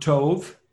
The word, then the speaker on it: Tov: Okay, the word Tove, T-O-V with a long O, Tove means good or goodness in Hebrew.